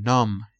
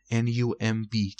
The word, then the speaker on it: numb: واژه ششم numb. numb که بی آخر تلفظ نمیشه.